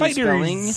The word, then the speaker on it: FighterZ: Dragon Ball FighterZ, boy band pronunciation Fighters.